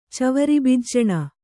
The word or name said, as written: ಚವರಿಬಿಜ್ಜಣ